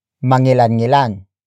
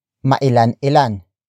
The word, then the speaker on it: mangilan-ngilan: The opposite of marami-rami is mangilan-ngilan. Mangilan-ngilan. Mangilan-ngilan. Mangilan-ngilan is spelled as M-A-N-G-I-L-A-N hyphen N-G. I-L-A-N. It used to be pronounced as mailan-ilan, which features the root word ilan, which apart from how many, can also mean some.